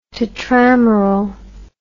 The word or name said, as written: tetrameral